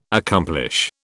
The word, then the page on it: accomplish: British English